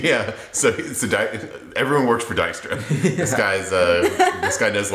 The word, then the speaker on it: Dijkstra: Apparently, she's also there as a representative of a person named Dijkstra, who is the... – He's Dijkstra. – Dijkstra? – If it's like the Dutch pronunciation, it's Dijkstra. – Oh, maybe it's Dijkstra, yeah.